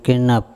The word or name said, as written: kenap